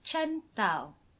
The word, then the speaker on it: Chen Tao: So we're Chen Tao. Sauce for that pronunciation. – I think that's right. More of a D than a T, that's the right.